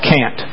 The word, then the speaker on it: Kant: and where the young guy in there, Clark Kent, or Kant, K-A-N-T, but he pronounced it Clark Kent, on Sunday mornings was one way.